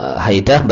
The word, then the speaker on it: Haidah: Dikatakan oleh At-Tabarani dan Al-Hakim dari Muawiyah ibn Haidah. Haidah bacanya fi fatil muhmalah. Muhmalah itu tidak bertitik. Yaitu haknya dan dalnya. Hak sama dalnya muhmalah tidak bertitik. Dua-duanya dibaca fathah. Haidah.